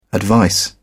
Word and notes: advice: British English

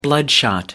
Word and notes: bloodshot: American English

